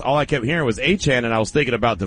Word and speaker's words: Etienne: But either way, when Jacksonville drafted him, that's all we ever heard was, oh, that's Travis Etienne.